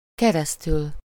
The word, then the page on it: par: France; [paʁ]